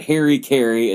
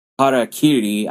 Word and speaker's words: harakiri: and not Harry Carey, it's Harakiri. I don't know why people want to pronounce it fucking Harry Carey.